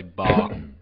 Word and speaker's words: Baugh: It's spelled like laugh with a B, but it's pronounced Ba, I'm guessing. – Yeah, I say Ba.